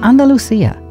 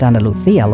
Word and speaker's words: Andalucía: They include Andalusia, pronounced Andalucía locally, Asturias, Castilla de la Mancha, Madrid, and Valencia.